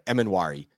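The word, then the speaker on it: Emmanwori: There seems like there should be another vowel between the N and the W, but there's not. Emenwari. – Yeah, that I think you said it right. Emenwari.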